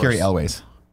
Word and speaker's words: Cary Elwes: And some behind-the-scenes drama with Cary Hughes. Is that how you say his name? Made the franchise worse. – Cary Elwes.